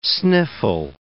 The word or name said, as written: sniffle